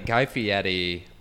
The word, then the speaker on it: Guy Fieri: Guy Fieri. – Ooh, you said it right. Good for you.